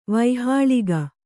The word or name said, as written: ವೈಹಾಳಿಗ